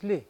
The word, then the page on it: li: Mokilese